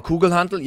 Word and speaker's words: Kugelhantel: Kugelhantel, right? That's how you say it in German. That's the Kettlebell. Kugelhantel, yes.